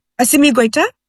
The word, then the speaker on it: Assimi Goïta: Did I say that right? – Assimi Goïta. – Assimi Goïta. Is that like Spanish or French? – It's in his local language.